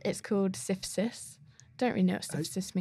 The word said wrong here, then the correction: Sisyphus: How's that spelled? – It's spelled S-I-S-Y-P-H-U-S. – That's Sisyphus. That's the Greek... – I thought it was Sisyphus.